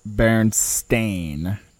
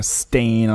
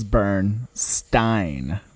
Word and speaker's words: Berenstain: So it is correctly pronounced Berenstain bears, as in like a stain on a t-shirt.